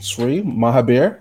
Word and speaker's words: Sri Mahabir: Today we have a special guest, Sri Mahabir.